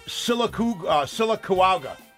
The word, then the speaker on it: Sylacauga: The first modern instance of a meteorite striking a human being occurs in the town of Sylacauga, Alabama. – I'm sorry, what is that? – That's Sylacauga. How do you spell that? – It's changed already? – S-Y-L-A-C-A-U-G-A.